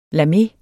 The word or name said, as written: LAME